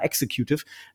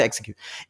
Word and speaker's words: Executive: CEO wäre es, wenn wir ein privatwirtschaftliches Unternehmen wären, dann wäre ich tatsächlich der Chief Executive Officer, der in Deutschland fast immer falsch ausgesprochen wird. Leute sagen immer Executive. – Executive, ja? – genau, das heißt Executive.